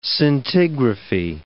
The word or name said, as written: scintigraphy